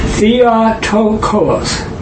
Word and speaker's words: theotokos: This concept was that Mary is the God bearer, and that, you know, that Greek word is theotokos, four syllables. – It's spelled. – Yeah, it's like Theo, which is God. T H E O T O K O S.